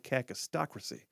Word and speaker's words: kakistocracy: K-A-K-I-S-T-O-C-R-A-C-Y, cacistocracy.